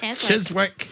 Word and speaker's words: Chiswick: Chiswick.